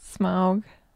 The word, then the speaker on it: Smaug: Smaug. – See, Smaug. That's what he that's puts it. He says Smaug. Schmaug.